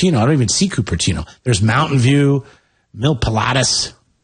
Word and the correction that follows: Milpitas: Cupertino. – Am I saying that right? Milpitas. – Milpitas.